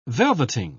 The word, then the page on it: velveting: British English